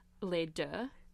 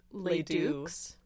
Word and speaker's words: Les Deux: Les Deux. Like, okay. – Both. – But, yeah. Like, but obviously no one speaks French. – So they would always pronounce it Les Dukes.